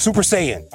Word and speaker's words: Super Saiyan: Super Saiyan.